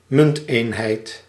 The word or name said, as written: currency